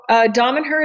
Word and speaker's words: Damanhur: And can you please speak about Damanhur? Did I pronounce that well? Say that again. – Damanhur, yeah. – Yes. – So Damanhur is a spiritual community that was founded over 45 years ago near Torino in the north of Italy in the Piedmont region.